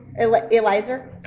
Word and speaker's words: Eliezer: We're going to understand later on, he actually says someone, his name is Eliezer. I don't know if I'm saying that right. Am I saying that right? Anybody? – Eliezer.